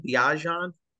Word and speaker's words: Bijan: Bijan.